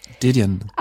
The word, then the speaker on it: Didion: Jag tar mig friheten och att uttala det pyttelite franskt. Det är ju egentligen en Didion.